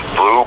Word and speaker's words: Bleu: the easiest way is to go to Blue, B-L-E-U, it's spelled French, Native American, but that's my spelling of my name.